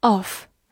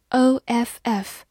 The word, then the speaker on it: off: Off, O F F, off，字母 O 发短音 o，F F 发 f，off，或者美式发音字母 O 发长音 off 也是可以的。